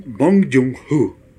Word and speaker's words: Bong Joon-ho: Es Bong Joon-ho. No, Bong, es que hay que darle pronunciación porque si no luego hace uno el ridículo. – Bong. Bong Joon-ho.